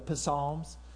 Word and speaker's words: Psalms: And if you don't know how to spell it, you say it phonetically, you say the Psalms, because that's how they say it. Anyway, that's why Spanish is so much better. You don't have a P in front of it.